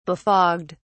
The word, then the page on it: befogged: British English